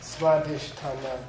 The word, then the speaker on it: Svadhisthana: Svadhisthana.